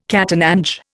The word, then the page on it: catananche: Indian